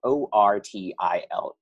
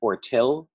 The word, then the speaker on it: Ortile: So it's spelled Ortile. And strangely enough, people tend to cut it off at Ortile, or they'll do sort of the phonetics of it, like Ortile, like you're choosing between granite, marble, or tile.